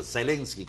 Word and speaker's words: Zelensky: así se pronuncia, Zelensky con Z.